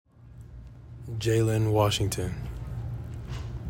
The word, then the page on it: Jalen Washington: JAY lihn WASH ING ton